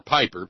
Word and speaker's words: Pieper: or Piper, P-I-E-P-E-R, if it's properly pronounced German, it's Pieper. If it's Americanized, it's probably pronounced Boehner. No, P-I-E-P-E-R.